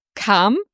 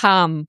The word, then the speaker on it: Cham: Und die Stadt heißt aber Cham und es wird geschrieben Cham. – Ja. – C-H-A-M. Ich bin schon dafür jetzt dankbar, dass ich jetzt weiß, wie diese Stadt ausgesprochen wird, weil ich habe das auch schon mal gelesen.